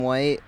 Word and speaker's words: White: My name is Adam White, A-D-A-M, White, W-H-I-T-E.